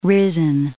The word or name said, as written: risen